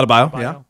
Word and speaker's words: Adebayo: And, you know, Miami was without Jimmy Butler and Adebayo. Is that how you pronounce his name? – Adebayo. Yeah.